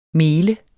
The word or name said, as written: mel